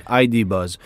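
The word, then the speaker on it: ID. Buzz: Vaut mieux le prononcer en anglais, ID Buzz.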